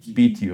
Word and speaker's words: Beatty: Jetzt, Martin, musst du mir helfen, spricht man den Betty aus oder Beatty oder wie sagt man zu dem? Beatty, oder?